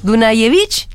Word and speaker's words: Dunayevich: Andrés Dunayevich. Lo dije bien, Andrés. Más o menos, me había salido más natural. si es cordobés es Dunayevich. – Dunayevich, bueno, perfecto.